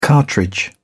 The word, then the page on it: cartridge: British English